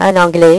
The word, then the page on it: un anglais: French